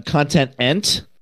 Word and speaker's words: Content-ent: Hi, I'm Content-ent. – Oh, I said ENT. Oh, sorry. Sorry.